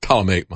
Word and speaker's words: Ptolemaic: There was a model for the movement of the planets, the Ptolemaic model. I guess you don't pronounce it P. Ptolemaic model.